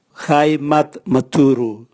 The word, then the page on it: gaimatmaturu: Raga